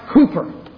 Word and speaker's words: Cowper: It's spelled C-O-W-P-E-R, but it's pronounced Cooper.